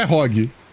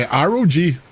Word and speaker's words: ROG: Não é ROG, é ROG. No mundo inteiro fala ROG. No Brasil, o brasileiro pegou e falou ROG.